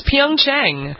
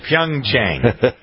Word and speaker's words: Pyeongchang: It's not hard. It's Pyeongchang. – Okay, Pyeongchang.